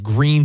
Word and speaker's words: Gruene: When my wife's from New Braunfels, Texas, and there's a really cool little town called Green, Texas. It looks like it's spelled Gruene, G-R-U-E-N-E.